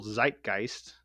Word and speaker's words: zeitgeist: but sort of in the cultural zeitgeist. Is that correct, Adam? – That's how you say it. Yep.